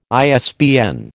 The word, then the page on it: ISBN: American English